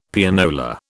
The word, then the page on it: Pianola: British English